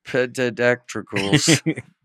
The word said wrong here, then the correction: pterodactyls: Oh, pterodactyls. – There's another silent P in there.